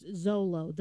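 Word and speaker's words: Xolo: Yeah, but they apparently it's pronounced Zolo, the Zolo dog, little dog.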